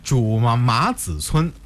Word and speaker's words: 朱麻子村: 那看来我这个读音没有错哈，这个名字应该是朱麻子村啊，这个如果换成现在这个姓的话，应该是朱麻子，也是这个意思，对不对？